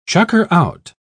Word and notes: chucker-out: British English

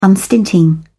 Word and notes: unstinting: British English

